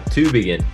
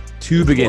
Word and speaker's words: Tübingen: Tell me, how do you pronounce the town that you're in? – Tübingen. – Tübingen. – A little emphasis on the Tübingen.